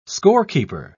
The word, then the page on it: scorekeeper: British English